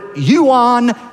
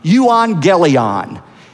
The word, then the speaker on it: euangelion: That word gospel in Greek is the word euangelion. It's a hard G. In English, we transliterate it to a soft G and we say evangelist. That's where the word comes from. Euangelion.